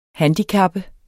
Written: handicap

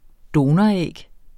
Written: donoræg

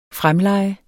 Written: fremleje

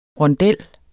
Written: RONDEL

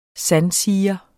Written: sandsiger

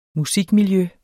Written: musikmiljø

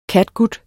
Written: catgut

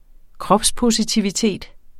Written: kropspositivitet

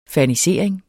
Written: FERNISERING

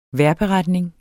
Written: vejrberetninger

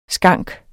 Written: skank

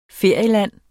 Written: ferieland

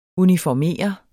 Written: uniformere

